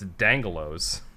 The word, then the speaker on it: D'Angelo's: Yeah, you're supposed to say D'Angelo's because it's got an accent mark between the D and the A.